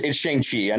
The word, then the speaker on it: Shang-Chi: No, it's Shang-Chi.